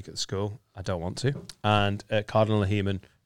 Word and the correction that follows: Heenan: Heeman. Said it wrong. Heeman. Cardinal Heenan.